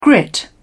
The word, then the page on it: grit: British English